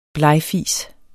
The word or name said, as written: blegfis